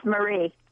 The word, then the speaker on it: Marie: Mari, am I not saying your name right? – It's Marie. – It's Marie. The E fell off.